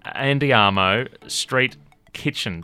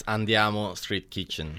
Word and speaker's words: Andiamo Street Kitchen: Did I say that right, Farrah? – Yes, you sure did. – Say it in the Italian flair. – It's Andiamo Street Kitchen.